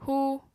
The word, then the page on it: hú: apatani